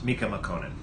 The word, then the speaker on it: Mika Mokkonen: It's Mika Mokkonen.